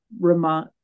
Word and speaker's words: Ramon: And my maiden name, Ramon, is rhymes with a word for fat, and so that was a favorite nickname of people's.